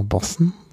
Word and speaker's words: boatswain: Nämlich da hörte man eine Bootsmannspfeife oder Bootsmannpfeife oder auf Englisch Boston's äh, Call, genau, Boston. Kann man, weiß man gar nicht, wie man das schreibt. Schreibt man Boatswain, wird Boston ausgesprochen, das finde ich sehr faszinierend.